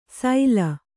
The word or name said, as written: ಸೈಲ